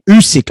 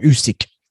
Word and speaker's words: Usyk: Usyk, I keep saying his last name wrong, Usyk.